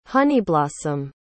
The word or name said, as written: honeyblossom